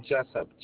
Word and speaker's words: Jessup: First name Shed, S-H-E-D. Last name Jessup, J-E-S-S-U-P.